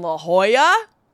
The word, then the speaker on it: La Jolla: I want to say thank you to everybody who came out to the shows in La Jolla. I said it right, did I? Okay, like it's a word that always escapes me, like many words and we had a we had a really great time.